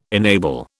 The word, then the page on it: enable: British English